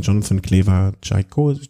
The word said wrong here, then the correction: Caicedo: wie, Caicedo. Caicedo. Wie kann ich mir das merken? Cais, wie ein S, Caicedo. Äh, v.